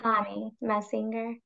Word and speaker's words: Chanie Messinger: It's Dr. Chanie Messinger.